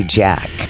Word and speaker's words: Jatk: In the past, we've always said J-A-T-K, but it's actually Jack. The silent T.